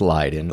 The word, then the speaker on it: Leyden: That's how you pronounce that. – It's pronounced Leiden.